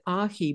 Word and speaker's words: aji: And you pronounce it ahi, but it's really spelled A-J-I, isn't it?